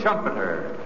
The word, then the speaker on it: Schumpeter: Joseph Schumpeter, whose name rhymes with trumpeter.